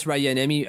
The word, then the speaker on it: Rajaniemi: Obviously, Matthias Rajaniemi, I think I pronounced that right. I think it's Rajaniemi, not Rajaniemi, like I used to do it.